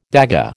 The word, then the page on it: dagga: American English